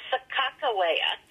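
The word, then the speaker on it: Sacagawea: Now, the captains wrote her name a minimum of 20 times in the journals, and every single time it was broken into syllables because they spelled phonetically, and it was Sacajawea with a hard G sound.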